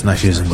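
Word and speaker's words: Gnashism: That's Nashism with a G in front, silent G.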